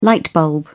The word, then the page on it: light bulb: British English